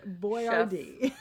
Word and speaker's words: Boyardee: It's all one word. It's Boyardee. Chef Boyardee. – No, because we are it's a regional dialect and it is pronounced Boyardee.